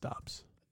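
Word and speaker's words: Doubs: Dubs onto there. – I think it's pronounced Dobbs. – Dobbs. – I think. – Romeo Dubs. Romeo Dobbs.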